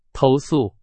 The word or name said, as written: 投诉